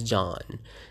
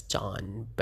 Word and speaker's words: Can: So apparently for Turkish, they spell it as C-A-N. My a little bit trivia for everyone. Yes, so C-A-N, if you would look at it. I don't really go into particulars about, but they pronounce it as John. But if you would see like this how it's spelled is C-A-N.